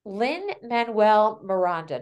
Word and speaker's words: Lin-Manuel Miranda: But as I was looking and just doing a little research on small businesses, brushing up into our current environment, Lin-Manuel Miranda, did I say that correctly? – Yep.